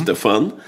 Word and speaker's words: Стефан: Стефан.